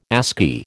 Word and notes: ASCII: American English

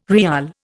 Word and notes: riyal: Indian

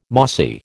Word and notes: mossy: American English

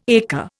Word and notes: acre: Indian

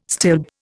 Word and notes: stilb: Indian

